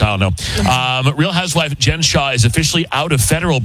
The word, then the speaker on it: Jen Shah: And Real Housewife Jen Shah, did I say it right? – Mm-hmm.